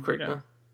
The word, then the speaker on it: Crichton: Uh, how do you say his name? Michael Crichton. – Crichton Michael Crichton. – Yeah.